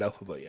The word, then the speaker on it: Elphaba: Yeah, Glinda and Elphaba, yeah.